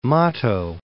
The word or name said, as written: Mato Tepee